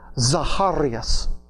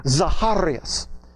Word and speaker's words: Zacharias: Zechariah in Greek is pronounced Zacharias. That's his name in the Greek, Zacharias.